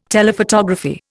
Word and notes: Telephotography: Indian

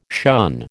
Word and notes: shun: American English

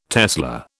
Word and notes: tesla: British English

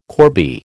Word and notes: corbie: American English

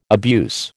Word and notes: abuse: American English

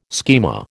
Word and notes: schema: American English

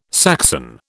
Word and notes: Saxon: British English

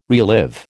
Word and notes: relive: American English

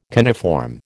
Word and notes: coniform: American English

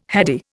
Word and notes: heady: Indian

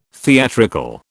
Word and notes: theatrical: British English

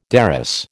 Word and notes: derris: American English

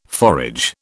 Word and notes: forage: British English

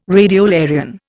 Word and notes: radiolarian: Indian

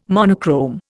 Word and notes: monochrome: Indian

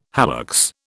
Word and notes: hallux: British English

